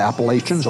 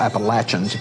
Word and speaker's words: Appalachians: You've got the Appalachians, or Appalachians, if you pronounce it correct, the way they do.